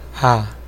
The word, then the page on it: toward: [tə.ˈwɔɹd]; /tə.ˈwɔːd/; /twɔːɹd/; /tɔː(ə)ɹd/